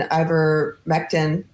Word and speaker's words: ivermectin: Hydroxychloroquine and ivermectin, that's how you say it, right? Ivermectin are no longer under patent. Dollar signs. – That is correct.